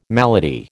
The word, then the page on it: malady: American English